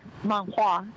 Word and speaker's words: manhua: So I was able to add to my reading collection all of these great, in China, like manhua is how we would pronounce it there, and also Korean manhua, but also manga from different countries, and really look at it because the styles are still slightly different.